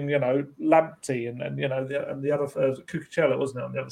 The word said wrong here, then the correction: Cucurella: Was it, could you have, absolutely murdering the pronunciation there again? – Kukureya, that's the one.